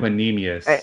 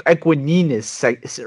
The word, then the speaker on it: Equanimeous: Equanimeous. Equanimeous.